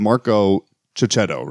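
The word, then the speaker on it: Checchetto: And the artist is Marco Cicchetto, right? I think that's the correct way to say it. – Yep.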